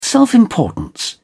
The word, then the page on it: self-importance: British English